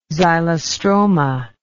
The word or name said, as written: xylostroma